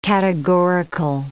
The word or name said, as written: categorical